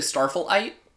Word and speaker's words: Starflight: It's actually Starflight. – Starflight? – Yeah. – Like you raise it on the second syllable.